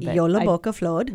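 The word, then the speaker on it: Jólabókaflóð: but anyway, I just love Jolabokaflod. I was going to ask you to say it again. – Jolabokaflod.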